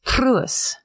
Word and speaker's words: Truus: Let's first talk about this is how she pronounces it on the podcast, so I'm just imitating a Dutch woman who's clearly, this is how you're supposed to say it. – Okay. – Truus. – Ooh.